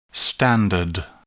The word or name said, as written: standard